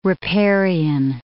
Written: riparian

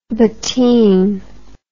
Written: bottine

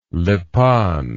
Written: Lipan

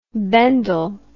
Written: Bendel

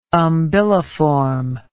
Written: umbiliform

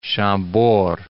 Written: Chambord

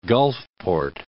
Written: Gulfport

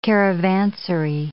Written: caravansary

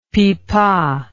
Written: pipa

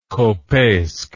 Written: kopeisk

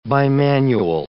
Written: bimanual

